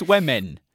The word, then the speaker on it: women: for mispronouncing the plural of woman, because apparently it's women.